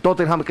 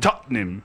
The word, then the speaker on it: Tottenham: decimos, en vez de decir Tottenham, decimos Tottenham. Tottenham. Pues Tottenham. – Son dos sílabas.